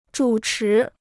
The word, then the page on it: 主持: zhǔ chí